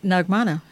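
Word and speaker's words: Naghmana: So I tell them, leave the H out and just pronounce it Nagmana, and it works.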